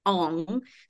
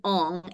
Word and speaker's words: Ng: her name's Kim Ong. So it's spelled NG. So Kim Ong and she's a badass, but we want her to lose.